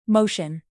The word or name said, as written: motion